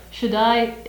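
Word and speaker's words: Shaddai (שַׁדַּי): I'm not sure if I pronounced, is it Shaddai or? – Shaddai, that's good, uh-huh.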